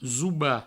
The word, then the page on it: zobar: zouba